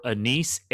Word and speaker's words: Anicet: And A.J. stands for Anice, A-N-I-C-E-T, but the T is silent.